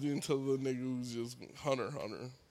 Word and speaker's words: Hunter Hunter: I feel like Hunter X Hunter. I know Hunter Hunter is the correct way to say it.